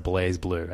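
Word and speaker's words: BlazBlue: I know it's spelt like Blaz Blue, but I'm pretty sure it's pronounced Blaze Blue.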